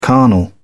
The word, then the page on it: carnal: British English